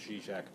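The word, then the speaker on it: Žižek: And and also the things that uh, Zizek, um, spelled Zizek but pronounced Zizek, and um, Silverman have to say about it.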